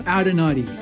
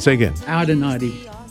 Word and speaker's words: Audagnotti: Audagnotti. – All right. – Gavin Audagnotti. – Say again. – Audagnotti. – It's like an Audi, like a naughty.